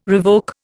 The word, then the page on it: revoke: Indian